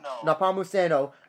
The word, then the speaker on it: Nepomuceno: How do you pronounce her last name again? – Nepomuceno against opponents that have like no wrestling or ground game.